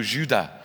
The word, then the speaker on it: Judas: Every time we go to France, and our lad is called Judah, but the French, they pronounce the word Judas without the S on the end, so it's also Judah.